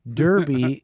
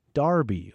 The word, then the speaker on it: Derby: Derby is actually pronounced Darby over in the UK.